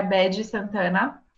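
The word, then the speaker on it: Baddie Santana: É, a última pessoa que eu vou, vou falar é a Bad Santana. Isso se escreve B-A-D-D-I-E Santana.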